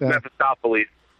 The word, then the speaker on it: Mephistopheles: Mephistopheles.